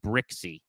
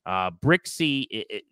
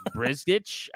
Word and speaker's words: Brzykcy: Brixey, that's how you say his name. It's spelled B-R-Z-Y-K-C-Y. So it looks like... – Can I buy it now? – Brizgich? I don't even know how you would pronounce that based on what it looks like, but it's Zach Brixey.